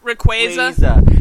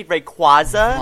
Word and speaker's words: Rayquaza: Rayquaza. – Because, you know, as a kid, I always say Rayquaza. – Rayquaza.